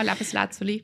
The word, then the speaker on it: Lapis Lazuli: Das war die Lapis Lazuli, wie spricht man es richtig aus? – Genau, Lapis Lazuli, ja.